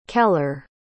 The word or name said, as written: keller